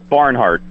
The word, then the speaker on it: Barnhart: Barnhart. – Barnhart? Like the way it's spelled? – Yes. – Like the way it sounds? – B-A-R-N-H-A-R-T. – A-R-T.